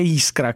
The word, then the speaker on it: Iskra: Sai che io un po' sono russo, sarebbe Iskra come pronuncia, ma va benissimo.